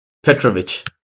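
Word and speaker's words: Petrović: Petrovic.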